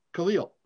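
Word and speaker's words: Kalil: Khalil. – Yep. Rhymes with Shlemiel, Schlemazel, Hassan Pfeffenkorn, Matt Khalil.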